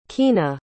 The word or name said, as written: Keana